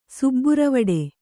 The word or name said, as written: ಸುಬ್ಬುರವಡೆ